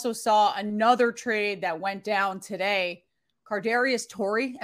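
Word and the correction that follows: Toney: It's Tony. Correct? It's straight up Tony.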